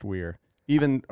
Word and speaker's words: Wier: I mean, that was one of the major problems of naming it that, is that no one can spell or pronounce my last name, even though it's Wier, like the question. – Yeah. – But it's always pronounced Wier. Even early on, before the company was Wier Stewart, it was Wier House.